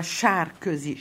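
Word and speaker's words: Sárközy: Ça se prononce en hongrois Sarkozy. Char, c'est la boue.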